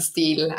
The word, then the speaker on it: Steil: Aber Steil wird halt so ausgesprochen, wie du es ausgesprochen hast. Aber für die Zuschauer, die mich von meinen Streams oder so kennen, würde mich wahrscheinlich sehen. Auf Englisch sage ich dann Steel, aber hier passt das schon sehr gut so.